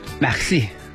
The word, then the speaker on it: MERCI: 跟我学这个发音，法语的发音啊，小舌头你能弄出来吗？ – 不能。 – Maxi。